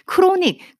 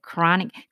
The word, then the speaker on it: chronic: chronic 여기서도 ch chronic 그 CHR 들어가요. 바로. 그 같이 눌러주세요. 크로닉, 크로닉 이렇게 하시면 안 돼요. chronic. 같이 눌러주셔야 돼요.